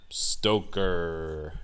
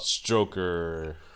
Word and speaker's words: Stoker: Yep, by Bram Stoker. Or is it I tell you what. – Stroker? – No, it's Stoker.